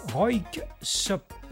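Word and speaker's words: Röyksopp: c'est Roik Sop. Difficile à prononcer, Roik Sop, voilà, R-O-Y-K-S-O-2-P, un album sorti en 2001 baptisé Melodyheim, beaucoup plus facile à prononcer.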